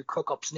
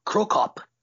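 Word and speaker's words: Cro Cop: So, I'm kind of mixing the two sounds when I do Krokop's name, both an English pronunciation and a Japanese pronunciation of a foreign word. So, instead of just Krokop, it's Krokop. So I'm sort of adding that little extra sound at the end, which I guess was more of a nod to the Japanese audience than to the foreigners.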